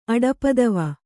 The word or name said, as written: ಅಡಪದವ